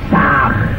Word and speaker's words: Sah: Han se escribe con las letras H-A-M. Sah se escribe con las letras S-A-H. La H suena siempre como J.